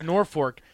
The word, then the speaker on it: Norfolk: The first time I ever anchored, it was talking about Norfolk, and I said it that way. But it's pronounced Norfolk.